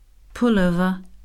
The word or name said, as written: pullover